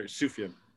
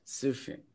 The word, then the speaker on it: Sufjan: It's a silent J, my brother. Sufjan. – Sufjan.